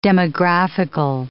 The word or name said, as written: demographical